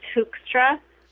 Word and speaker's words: Hoekstra: It's Hoekstra. I'll spell that too. It's H-O-E-K. – Okay. – S-T-R-A.